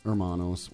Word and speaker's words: Hermanos: The H is not pronounced. – Hermanos.